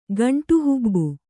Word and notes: ಗಂಟುಹುಬ್ಬು: gaṇṭu hubbu

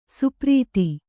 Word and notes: ಸ್ಪರ್ಧಿ: spardhi; Kannada

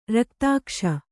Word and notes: ರಕ್ತಾಕ್ಷ: raktākṣa

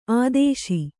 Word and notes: ಆದೇಶ: Kannada

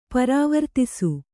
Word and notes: ಪ್ರವರ್ತಿಸು: Kannada

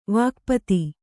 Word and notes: ವಾಕ್ಪತಿ: kannada

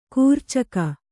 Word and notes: ಖರ್ಚಿಕ: kharcika

